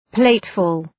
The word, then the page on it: plateful: ‘pleıtfʋl